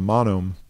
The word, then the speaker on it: Monome: Monome, M-O-N-O-M-E. – Mm-hmm. – Monome is phonetically how it's, you know, spelled.